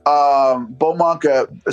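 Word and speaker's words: Baux Monk: Bowmunk. So it's BAUXMONK. That's how you say it, all one word.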